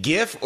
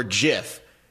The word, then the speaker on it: Giff: So, the outside linebacker coach, is it GIF or JIF, right? That's the running joke. – Yeah. – It's spelled G-I-F-F. We'll go with JIF.